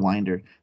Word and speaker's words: Winder: So I've said 2 different pronunciations, but we'll go with Winder. – It is. I just looked it up. It is Winder. That is correct.